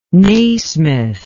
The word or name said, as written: naismith